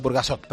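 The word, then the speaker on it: Burjassot: Buenos días, Pulpo y compañía, desde Burgasot, en Valencia. ¿Esto está bien pronunciado o no? – A ver, otra vez. – Burgasot. – Burgasot, perfecto.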